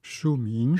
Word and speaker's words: Shuming: Shuming, dit-on, ou Suming? – Shuming. – Shuming, bon, je vous laisse le prononcer correctement, mieux que moi.